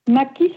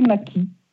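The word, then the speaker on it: Macchi: M-A-2-C-H-I. – D'accord. Et ça se prononce Mackie ? – Ma belle famille a décidé que ça se prononçait Mackie. Voilà. – Ah oui, pourtant. – Donc je fais comme ma belle famille. – Mackie, bon. Ok. Merci. Parce que C-H-I en italien, c'est Machi.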